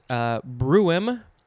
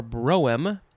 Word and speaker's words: brougham: There's a couple ways to say it. Bruem or broem, I think that's what it's saying. It is spelled, weirdly to me, B-R-O-U-G-H-A-M.